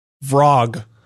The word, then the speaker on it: Vrog: It's called Vrog. It's a game about being a frog in VR. – Nice. – It's spelled V-R-O-G, like frog, but with VR at the front.